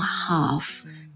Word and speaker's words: half: 我们来看一下歌名里面的 Half 这个单词啊， H-A-L-F，它有两个读音呢。我们英式的话读作 half，美式的话会要读成 half half。